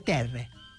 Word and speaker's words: terra: Terra, que es pronuncia terre.